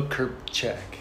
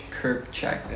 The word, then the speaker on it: Krpicak: Yeah, my name is Beau Krpicak. Krpicak. Yeah. We don't pronounce the I, but there is an I in the Yeah.